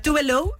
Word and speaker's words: Tove Lo: O sea, se llama Tove Lo, o sea, Tove Lo, es la pronunciación, pero su nombre real es Eva Tove Elsa Nilsson.